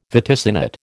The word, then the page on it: vaticinate: American English